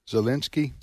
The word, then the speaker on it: Zelensky: Listen, I have great admiration for this fella, Zelensky. Is that the correct pronunciation? Correct.